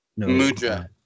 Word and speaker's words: mudra: Mudra. – Mudra.